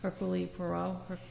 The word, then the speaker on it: Hercule Poirot: Hercule Poirot.